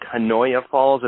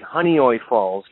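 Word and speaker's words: Honeoye Falls: It looks like Hanoya Falls. It's actually Honeyoy Falls, New York.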